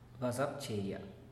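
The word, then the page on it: basabcheya: Teotitlán del Valle Zapotec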